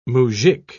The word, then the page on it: mujik: British English